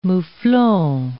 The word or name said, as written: moufflon